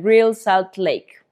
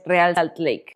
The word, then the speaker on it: Real Salt Lake: Y el estúpido América también cayó en penales contra el Real Salt Lake. O es Real Salt Lake. Ah, entonces lo dije bien.